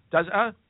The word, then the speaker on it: Dazhou: According to officials from the Environmental Protection Bureau, a city in China Dazhou, is that how you pronounce it? – Dazhou.